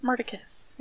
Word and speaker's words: Murticus: Murticus. – It sounds like murder kiss. Like a murder kiss.